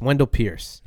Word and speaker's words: Wendell Pierce: Yeah, Wendell Pierce or Price. – Yeah. – He said it better than anybody else could, and he said it right to his face, which is a beautiful Pierce. Wendell Pierce.